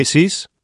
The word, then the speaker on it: Isis: Hay un mantrán que hay que pronunciar, es el mantrán de los misterios isíacos, que se pronuncia isis, se reparte en 2 sílabas, i-i-i-i-s-s-s-s-s-s-s-s. La s es como el silbo, dulce y apacible.